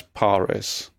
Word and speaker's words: Parris: Is that how you say your surname? Because there's an extra R in it, and I always read it as Parris. – You do say it Parris, but I would accept a pronunciation with a rolled R.